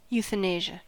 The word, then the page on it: euthanasia: American English